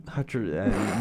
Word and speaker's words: Hutcherson: Hutcherson.